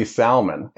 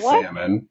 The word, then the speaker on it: salmon: I say salmon and most people say salmon. And so I always tell them, why you got to disrespect the L? – I'm hanging up.